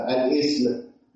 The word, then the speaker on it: الاسم: قال لك كلمة اسم، كلمة اسم انت مديك استمارة وبقول لك البيانات تكتب كده الاسم، ما تكتبليش تحتها همزة الاسم، ده يعتبر خطأ. لأن كلمة اسم أنا بنطق ألف، ما بنطقش همزة، ما بقولش ال ايه، لا أنا بقول الاسم.